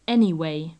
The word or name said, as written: anyway